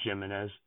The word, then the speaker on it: Jimenez: Oh, I did look it up. It's Jimenez. – So it is Jimenez. All right. – Well, Soft G.